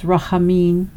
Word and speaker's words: rachamim: The sound in the Hebrew, rachamim, is pronounced similar to the word jalapeno, so it's rachamim.